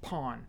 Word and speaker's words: pan: It's spelled Pam, but I was told it's pronounced pawn, almost like pawn shop.